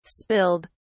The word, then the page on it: spilled: British English